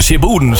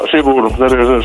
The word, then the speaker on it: Siddeburen: Siddeburen, zo spreek je het uit toch, of oorspronkelijk? – Ja, Siddeburen, zo spreekt ze gauw uit.